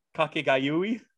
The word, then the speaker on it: Kakegurui: But then sometimes Kakegurui goes too far with a pirate lizard tongue girl with a gun. Don't you dare laugh at my pronunciation. – One more time, please. Kakegurui. – Kakegurui.